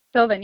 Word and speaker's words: Sylvan: Am I pronouncing it right? Sylvan Learning Centers? – Sylvan, yeah, I think that's correct.